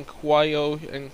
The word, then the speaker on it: Cuyahoga: In Cuyahoga. Yep, that's how you pronounce that.